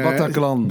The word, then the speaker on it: Bataclan: Eh, de reden dat je natuurlijk van links naar rechts wordt geslagen is dat je boek beter naar Batlakan uh, Bataclan. Bataclan. Bataclan.